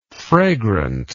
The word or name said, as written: fragrantness